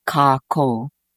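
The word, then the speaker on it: kākou: In the native language, we have a word called kako. That's a K-A-K-O-U. There's a makron over the two syllables, so it's kako.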